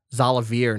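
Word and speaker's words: Xalavier: I don't know if it's Xavier Nelson or Zolavier Nelson. – Zolavier Nelson. Thank you.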